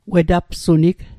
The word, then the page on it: wetapsunig: Mi'gmaq